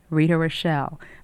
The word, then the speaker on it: Rita Rochelle: To get one, write to me, Rita Rochelle. My name is spelled R-I-T-A. Rochelle is R-O-C-H-E-L-L-E.